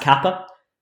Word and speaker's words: Kappa: Yeah, uh, K is actually pronounced Kappa. There's MG Kappa Vis.